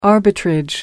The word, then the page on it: arbitrage: American English